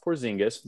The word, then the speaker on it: Porzingis: Kristaps Porzingis, nailed it on the pronunciation.